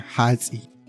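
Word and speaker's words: hats'i: Ajá, Hatsi.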